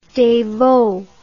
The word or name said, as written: devot